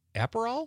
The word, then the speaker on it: Aperol: Liz from Sun Prairie says she's going to have tequila and soda with a lime or a splash of Aperol. Am I saying that right? – Yep, you got it.